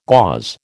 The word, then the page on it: gauze: American English